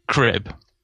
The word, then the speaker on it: Krb: So Vladimir Krib, pronounced spelt K-R-B, but I'm reliably informed pronounced Krib.